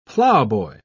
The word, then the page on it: plowboy: British English